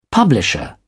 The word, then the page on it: publisher: British English